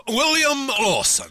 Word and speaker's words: William Lawson: William Lawson? – Non, William Lawson.